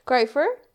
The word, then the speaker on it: Greifer: Just like I mentioned on the bio, today's guest is Matt Greifer. Did I say that right? – You did. – Okay, Matt Greifer, cool.